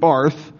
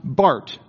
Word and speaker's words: Barth: You don't want to pronounce it Barth. You'll sound dumb. It's pronounced Barth, B-A-R-T-H. I know it sounds like Barth, but he's a Swiss man, so you call it Barth.